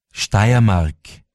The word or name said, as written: Steiermark